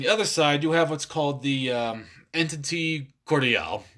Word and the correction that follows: entente: Is it entente? Entente. Yeah, I'm sorry.